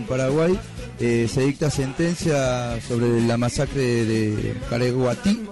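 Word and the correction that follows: Curuguaty: así se pronuncia, Curuguatí. Tiene problemas con el guaraní.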